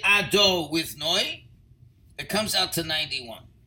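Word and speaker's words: Adonai: So, Amen, 91, is equal to Hashem, which is pronounced Ado with Noi.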